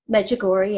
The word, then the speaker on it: Medjugorje: I know that then in 2005, you went with your mother-in-law to Medjugorje. Am I saying it right? – Medjugorje, yeah.